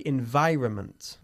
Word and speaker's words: environment: El medio ambiente decimos the environment, okay? It has an N which we hardly pronounce.